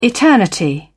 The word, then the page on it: eternity: British English